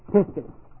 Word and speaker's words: pistis: H-O-M-O-L-O-G-E-O. Long O. Homologeo. This is not a synonym of pistis. P-I-S-T-I-S or pistis faith.